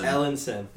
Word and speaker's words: Ellenson: Ellenson. – Yeah, it is a basketball player. – Spelled like Ellen, but with sun at the end.